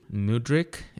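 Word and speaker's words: Mudryk: How do you say his name? – Mudrick is what I've heard.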